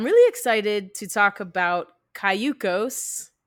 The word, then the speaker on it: Cayucos: I went through and I'm like, okay, I see that it's Spanish for canoe. So it's Cayucos.